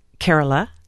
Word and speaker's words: Kerala: And in the state of Kerala.